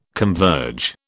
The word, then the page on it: converge: British English